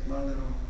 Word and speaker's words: Malraux: století, platí to, co kdysi vyjádřil velmi pregnantně francouzský spisovatel André Malraux, možná říkám, to jméno zná, píše se Malraux, ale vyslovuje se Malraux.